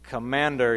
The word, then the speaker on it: commander: Because in African English, commander, you say commander, so it rhymes with Amanda.